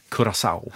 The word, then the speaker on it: Curaçao: Jag har fått stöd av producenten att man ska tydligen uttala det Curaçao. – Ja. Måste vägen till Curaçao gunga sau.